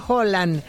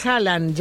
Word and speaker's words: Haaland: Ya que hablan de pronunciación, Haaland se pronuncia Holland, pero ya todos le dicen Haaland, ya fuera de Escandinavia.